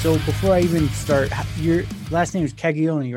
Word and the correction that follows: Caggiano: right? – Caggiano. – Caggiano. Okay. Yes.